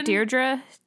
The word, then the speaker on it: Deirdre: Her name is D-I-E-D-R-E. Pronunciation? – So Deirdre? – Yeah.